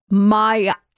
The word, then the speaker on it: Miami: It is said like Miami, Florida, but like Miami. – Miami. – It's not Miami. It's Miami. This one said, I lived in Louisville, Kentucky, and it's pronounced Louisville.